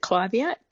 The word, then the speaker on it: clivia: Is it pronounced, is it clivia or clivia? – I call it a clivia. – Yep. But what's the question? – Yeah, yes. I was just wondering about its sun requirements and its water requirements. – So first of all, I pronounce it clivia, but it is a tomato.